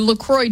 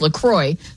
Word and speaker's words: La Croix: So officially, it's pronounced La Croix, so it rhymes with enjoy, not La Croix or La Croix, like the French might say it.